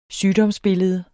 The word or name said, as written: SYGDOMSBILLEDE